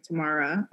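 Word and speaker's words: Tamera: Tia and Tamari, Tia and Tam- Tamara. Oh my god, I cannot pronounce their names.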